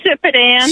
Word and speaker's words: Sipadan: to Saitapan, is that how you say it? How did you say that again? – Sipadan.